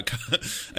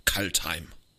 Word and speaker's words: Kaldheim: I guess that's how I pronounce it as a German. In English, they would probably go like, oh, Kaldheim. How would you pronounce it? In German, it's just Kaldheim. – Yeah, that's the same as us, Kaldheim.